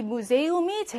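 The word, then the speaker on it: Museum: 시험에는요, 이 무세움이 제일 많이 나와요. 무세움은요, 오이라는 복모음이 있는 게 아니야. 여기서 끊으면 안 돼요. 요렇게 음을 따로 끊어야 돼요.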